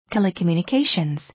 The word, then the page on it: telecommunications: tɛ̀ləkəmjùnəkéɪʃənz; テレコミュニケーションズ